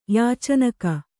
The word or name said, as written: ಯಾಚನಕ